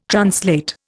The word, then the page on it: translate: Indian